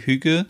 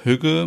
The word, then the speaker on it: hygge: Viele fragen sich auch immer, ja, wie wird Hüge eigentlich ausgesprochen? Also für mich ist das eigentlich so wie H-Ü-G-G-E, Hüge. Manche sagen aber auch Hüge, manche sagen Hike.